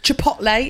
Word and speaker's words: Chipotle: Chipotle, is that how you say it? – Yeah.